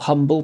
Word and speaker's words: Humble: spelt humble, pronounced Humble, and he became the DeLorean man.